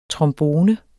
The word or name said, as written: trombone